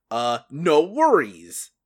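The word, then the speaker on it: NxWorries: No Worries. Or NX Worries, if you want to annoy people by pronouncing the X.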